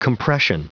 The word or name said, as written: compression